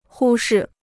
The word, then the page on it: 忽视: hū shì